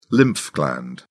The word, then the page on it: lymph gland: British English